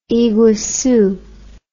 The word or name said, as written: iguacu